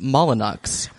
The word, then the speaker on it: Molyneux: McGee has a fitting in London with Monsieur Molyneux. – Right. – And he's over from Paris. – Though, according to Wikipedia, his name was actually pronounced Molyneux.